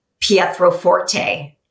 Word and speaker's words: Pietroforte: So my website and all of my handles are under Maria, like you might think it's spelled Pietroforte.